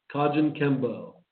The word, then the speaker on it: Kajukenbo: It's pronounced Kajukenbo Self Defense System.